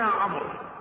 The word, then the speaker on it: عمرو: قال أنا عمرو. فتعجب صاحب إيلياء، فقال له هج لي اسمك. فقال له عين ميم، يترجمون له راء واو، هكذا تنطق عمرو وتكتب عمرو، ما يقرؤون عمرو، يقرؤون عمرو.